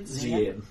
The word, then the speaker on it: Zian: Zian. Like a Z.